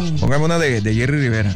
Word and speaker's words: Jerry Rivera: Pero los puertorriqueños dicen, aquí estamos con Jerry Rivera. – Ah, porque ellos no pronuncian la R sino la L. – Entonces, Jerry Rivera.